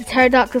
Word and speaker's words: pterodactyl: And the pterodactyl starts with a P. – I didn't know that. – Pterodactyl, you think it would be a T. – So it's a silent P.